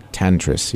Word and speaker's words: Tantris: Tantris, yes.